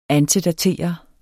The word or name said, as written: antedatere